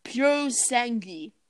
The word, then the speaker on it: Purosangue: Oh, no, excuse me, I've been pronouncing it wrong. Purosangue. Purosangue, that's it.